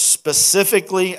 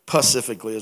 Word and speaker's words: specifically: We're going to look at five ministries of Jesus specifically outlined. Did I say that right? Because I usually mess it up. Specifically. Pacifically is what I used to say. Like the Pacific Ocean.